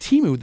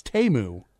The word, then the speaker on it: Temu: We've all been calling it Temu this whole time. Apparently it's Temu.